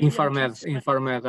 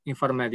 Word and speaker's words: INFARMED: And am I saying that right? PharmaMed? I think I may InfarMed. I think you're referring InfarMed.